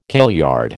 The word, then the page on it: kailyard: American English